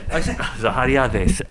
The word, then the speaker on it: Zahariadis: No, the D is a T-H. Zahariadis. You're saying it wrong.